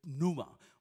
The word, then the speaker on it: pneuma: But in the Bible school, they taught it a little different for me. P is silent. Pneuma for me. That's how I learned.